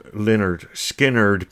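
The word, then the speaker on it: Lynyrd Skynyrd: Ja kuten tässä on jo tullut sitten muiden suunnan todetuksi, niin tuon ensimmäisen levyn nimihän on Pronounced Leonard Skinner.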